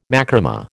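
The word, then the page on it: macramé: American English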